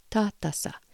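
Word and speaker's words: Tātasa': This is spelled T long A, T short A, S short A. Tatasa. So the T here is like an English T, but it's not the hard T like Tom or trick or truck or trouble.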